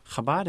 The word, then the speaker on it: Chabad: And in English, it's often spelled C-H-A-B-A-D, but the C-H is given the pronunciation from German or Russian.